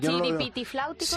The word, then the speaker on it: Chiripitifláuticos: Los chiripifláuticos, ¿lo he dicho bien? – Eso es difícil de decir. – ¿Chiripitifláuticos? – Sí, algo por ahí, sí.